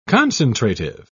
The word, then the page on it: concentrative: British English